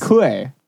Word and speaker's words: Kuei: His name is Kue. – K-U-E-I. – K-U-E-I.